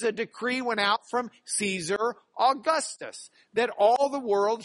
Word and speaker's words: Caesar: Remember in Latin, you pronounce the C hard, like a K. So instead of Caesar, you would pronounce it Kaiser.